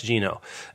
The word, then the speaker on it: Gino: It's George by Alex Gino, I believe is how you say the last name. – Yes. – Alex Gino.